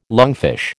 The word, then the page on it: lungfish: American English